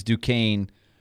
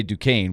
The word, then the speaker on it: Duquesne: BYU leads Duquesne 20-19, and D-Mace admitted earlier in the show, George, like I did, that I didn't know how to say Duquesne when I was a kid.